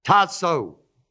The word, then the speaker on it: tasso: That word ordained is the Greek word tasso. T-A-S-S-O, long O.